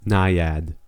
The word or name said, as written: naiad